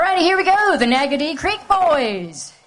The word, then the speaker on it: Naggodee: This is the Nagaddy Creek Boys, and that's spelled N-A-G-G-O-D-E-E.